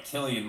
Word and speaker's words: Cillian: Let's just say his name right, Killian Murphy. – Killian Murphy.